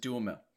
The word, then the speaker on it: Duhamel: Don't overthink it. It's just Duhamel.